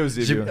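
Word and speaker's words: Eusébio: Ich weiß, Eusebio oder irgendwie sowas. – Ich glaube, EU, oder? – Ja. Eusebio. Ja, genau, ja, das ist richtig.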